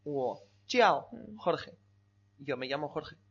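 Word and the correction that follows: Jiao (叫): Pronuncia más las vocales. – Sí. – Jiao. – Jiao. – Mucho mejor. Jiao.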